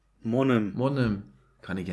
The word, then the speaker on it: Mannheim: die einen sagen Monem und auf der einen Hälfte sagt man Monem, die anderen sagt Mannem, aber Monem kenne ich das.